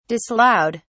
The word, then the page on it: disallowed: British English